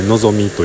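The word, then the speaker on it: 希味: 今日はあの初めて行ったお店やったんですけれども、京都のねビストロのぞみ、希望の木に味と書いてのぞみと読むらしいんですけれども、そのビストロのぞみさんで、ご飯を食べてお酒を飲んで帰ってきました。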